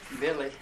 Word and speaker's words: Billy: Billy, B-I-L-L-Y, Brandt, B-R-A-N-T.